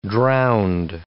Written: drowned

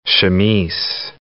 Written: chamise

